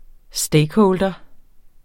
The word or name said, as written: stakeholder